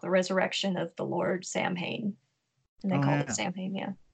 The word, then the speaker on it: Samhain: It sounds like Samhain, but it's actually pronounced Samhain.